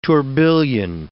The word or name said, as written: tourbillion